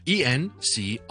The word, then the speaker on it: encrypt: 因为咧我哋好多香港人咧都读得麻麻地嘅，encrypt， encrypt， encrypt， encrypt。好多人见到 en 咧就会读成 n 嘅，咁就唔好 encrypt 嘅其实。